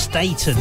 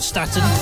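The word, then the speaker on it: Staton: And she confirmed her surname is pronounced Staten, not Staten. She also did a cover of Tammy Wynette's Stamp.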